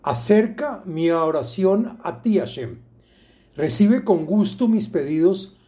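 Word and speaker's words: Hashem (השם): y deberá meditar el nombre de Hei Shin Mem, que se pronuncia Hashem.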